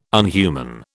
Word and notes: unhuman: British English

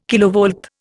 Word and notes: kilovolt: Indian

